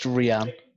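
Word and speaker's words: Rhian: And then about a level below him is Ryan Brewster. And then about three or four levels. Callum Hudson-Odoi was definitely the third best in that team. – It's pronounced Rian.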